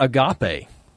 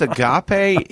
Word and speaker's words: Agape: How about Agape? – Hey, oh, wait. Yeah. The problem with Agape is everybody mispronounces it. – Look, Agape. – Yes. Agape.